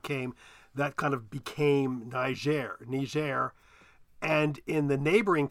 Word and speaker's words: Niger: Niger. And he got roundly criticized by the um, receptionist at the embassy, who said it should be pronounced Niger.